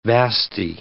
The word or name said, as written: vasty